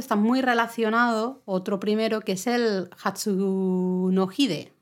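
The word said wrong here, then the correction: Hatsuhinode (初日の出): Siempre lo digo al revés. – Me pasa. Hatsuhinode.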